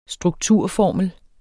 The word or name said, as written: strukturformel